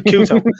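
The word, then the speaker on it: Couto: But, you know, the highlight of the whole game afterwards is just looking at John Aldridge's celebration with Fernando, I think I'm pronouncing this right, Cunto, was it? Sorry, Cuto.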